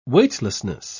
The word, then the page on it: weightlessness: British English